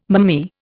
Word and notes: mammee: Indian

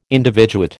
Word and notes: individuate: American English

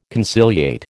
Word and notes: conciliate: American English

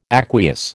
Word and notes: aqueous: American English

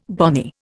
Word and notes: bonny: Indian

